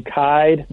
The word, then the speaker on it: Kyed: Follow me on Twitter at Doug Kide. My last name is spelled K-Y-E-D.